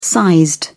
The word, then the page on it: sized: British English